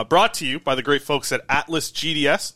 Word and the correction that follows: Atlas GDS: Atlas Brought to you by Atlas Goods, not Atlas GDS.